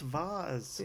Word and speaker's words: vase: I think it's pronounced Vaz.